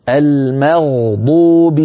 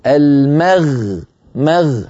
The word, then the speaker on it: المغضوب: اسمعوا أيها الإخوة، غيري هذه صواب، غيري هذه خطأ، المغضوب مغ مفخم الغين هذا صواب، المغ مغ هذه خطأ، فانتبه إلى ذلك.